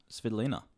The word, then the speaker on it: Svitolina: Winning in 3, another tight win in the quarters, and she's now through to the semi playing Elena Svitolina.